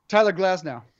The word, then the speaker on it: Tyler Glasnow: Tyler Glasnow.